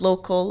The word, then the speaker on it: LCL: Welcome to LCL, pronounced local or living a creative life.